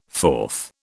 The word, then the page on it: forth: British English